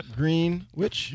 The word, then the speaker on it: Greenwich: Wait, is that how you say it? Greenwich Village? – Yeah. – Sure. – Greenwich? – You got it.